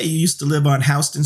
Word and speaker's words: Houston: And I give him props for saying Houston Street, even though he's only been to New York once last week. But okay. – That is the proper pronunciation of the New York Street.